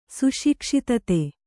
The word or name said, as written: ಸುಶಿಕ್ಷಿತತೆ